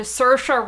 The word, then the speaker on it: Saoirse: Let's return to Saoirse Ronan. Which is not said at all how it's spelled.